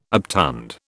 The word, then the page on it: obtund: British English